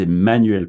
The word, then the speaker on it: Emmanuel: My name is Emmanuel Probst, and Emmanuel is spelled with two M's.